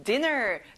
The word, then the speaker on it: dinner: 여기는 앞에 D에 강세를 넣어보세요. Dinner.